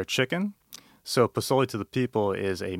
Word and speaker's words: pozole: I'm going to say this wrong, maybe pozole? – Pozole. – Pozole. To the people.